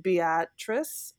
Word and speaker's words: Beatrice: And David Wiebe is born in a place called Beatrice, I believe it's pronounced. – Yes. Uh-huh.